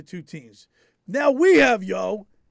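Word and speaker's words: Yeo: But you know what? While looking at the two benches, Mike Yao. Is that how you say his name? – Yo. Yeah, it's a very Philly name.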